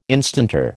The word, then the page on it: instanter: American English